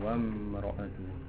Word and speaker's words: وامرأته: Donc quand il est là, tu le prononces pas. Tu fais la liaison entre la lettre qui précède et la lettre qui suit. Là, tu as le wa et là, tu as le mi. Donc, wa mra'atu. – La Hamza, on ne la prononce pas.